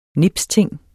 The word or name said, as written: nipsting